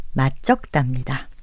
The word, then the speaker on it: 맛적다: 이 표현의 발음은 맞적답니다.